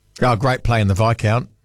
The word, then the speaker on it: Viscount: Was my first experience with the silent S was the Viscount. What a weird-looking word that was.